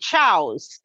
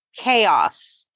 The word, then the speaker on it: chaos: But he was reading, and this one word, he was like, it created chows. And he's, I don't know this word. Let's look it up. And I'm like, can I just tell you what it means? And also, it's pronounced chaos.